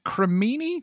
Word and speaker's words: cremini: How do you pronounce this? Is it cremini, the type of mushroom? – Yeah.